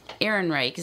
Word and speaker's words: Ehrenreich: Ehrenreich, is that how you pronounce it? – Yeah.